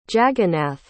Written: jagannath

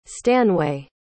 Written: stanway

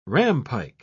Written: rampike

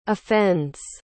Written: offends

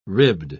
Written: ribbed

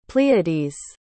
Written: pleiades

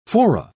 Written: fora

